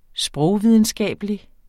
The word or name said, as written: sprogvidenskabelig